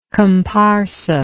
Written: comparsa

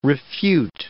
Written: refute

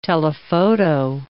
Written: telephoto

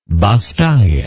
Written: basse taille

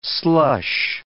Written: slush

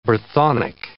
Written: Brythonic

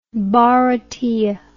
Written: Bharatiya

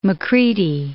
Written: Macready

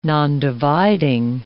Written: nondividing

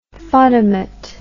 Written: fotomat

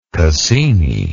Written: cassini

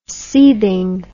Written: seething

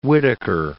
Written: Whittaker

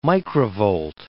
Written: microvolt

